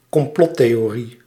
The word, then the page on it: complottheorie: /kɔmˈplɔt.teː.oːˌri/